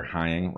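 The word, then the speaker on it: Heying: This is Dr. Heather Hying, rhymes with flying.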